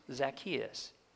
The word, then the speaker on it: Zacchaeus: Zacchaeus.